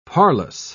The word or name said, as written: parlous